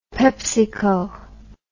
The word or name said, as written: pepsico